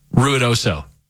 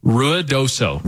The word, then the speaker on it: Ruidoso: So say it, how do you pronounce it, really? – Ruidoso. – Say it slowly so I can get it. – Ruidoso.